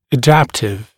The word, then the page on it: adaptive: ə’dæptɪv; э’дэптив